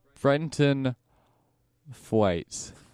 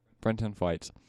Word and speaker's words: Brenton Thwaites: Brenton Thwaites. – Thwaites. – Brenton Thwaites.